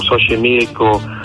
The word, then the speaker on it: Xochimilco: Y la X se pronunciaba en náhuatl como se pronuncia la Che en español. Entonces, todas las palabras como Chapultepec, o como Xochimilco, o como Xola, o como todas estas palabras que tenemos, o Xolo, son con X.